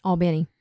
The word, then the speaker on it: Albany: And Glenn grew up in Albany. Isn't that how you say it? – Albany.